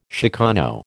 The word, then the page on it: chicano: American English